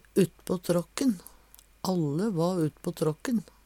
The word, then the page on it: uttpå tråkken: Numedalsmål